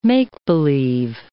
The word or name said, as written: make-believe